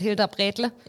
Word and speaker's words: Hilderbrätle: Habe ich das richtig gesagt mit Hilderbrätle? – Hilderbrätle, ja.